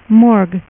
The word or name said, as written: Morg